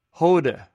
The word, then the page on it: hwde: greek